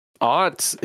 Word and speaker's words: aught: Aught. It's okay. So it's spelled with an A. A U G H T. It is the digit zero.